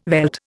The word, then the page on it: veld: Indian